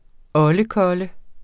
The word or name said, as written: oldekolle